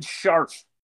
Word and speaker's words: Scharf: David Scharf. – Spell it. – S-C-H-A-R-F.